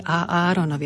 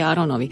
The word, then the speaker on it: a Áronovi: Pozor, aby sme zrozumiteľne vyslovili a Áronovi, aby nebolo Mojžišovi a Áronovi. To je zase niečo úplne iné.